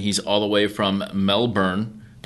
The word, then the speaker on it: Melbourne: There's no L. – There's no... – Melbourne. – Mebane. – Melbourne. – Mebane. Oh, okay.